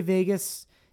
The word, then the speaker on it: vagus: Is it vagus or vagus? – I say vagus. – The only reason I ask is because it's not spelled like Vegas, Las Vegas.